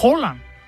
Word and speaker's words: Haaland: Haaland se dice Holland, pero nadie lo conoce.